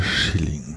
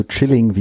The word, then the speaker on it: Schilling: Österreichische Schilling. 13, 30, boah, 13 österreichische Schilling entsprechen etwa ein Euro. Hast du Chilling wie Chillen ausgesprochen?